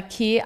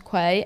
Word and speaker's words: Quay: This episode is brought to you by Key Eyewear. I don't know about you guys, but I always thought Key was pronounced Quay, and I have been saying it wrong for years.